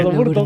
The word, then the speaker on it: Burton: Ricardo Burton, muy bien, Burton. Muy bien pronunciado.